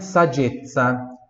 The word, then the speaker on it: saggezza: Mais uma palavrinha, saggezza. Saggezza. Repita, saggezza. Mais uma vez, saggezza. Última vez, saggezza. S-A-G-G-E-Z-Z-A. Saggezza. Esses dois Zs aqui, Z de zebra, tem som de TS como em pizza, tá?